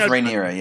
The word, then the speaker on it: Rhaenyra: is that how it's pronounced, by the way? Rhaenyra? – Rhaenyra, I always say. – Rhaenyra, okay. – I don't know. – I would go with Rhaenyra, yeah. Emphasis on the second.